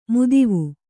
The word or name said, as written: ಮುದಿವು